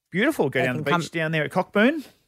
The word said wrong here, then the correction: Cockburn: Coburn, actually.